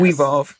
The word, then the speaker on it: We Evolve: Did I say that right? – Yes. We evolve. – We evolve. Yeah. I looked at it and said, okay, I don't know where to put the emphasis on the E.